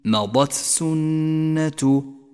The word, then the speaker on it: مضت سنة: إياك أخي القارئ أن تخلط بين همس التاء وحرف السين عندما تقرأ قوله تعالى فقد مضت سنة الأولين، مضت سنة، مضت سنة، فأعط كل حرف حقه ولا تخلط في النطق بين الحروف.